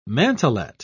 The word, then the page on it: mantlet: British English